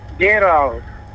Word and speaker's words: Gerald: Gerald. – Can you spell that? Did you get that, friend? – G-E-R-A-L-D.